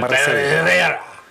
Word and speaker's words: Barrera: Maricela Barrera. – So, Maricela Barrera. – You can actually roll your R's.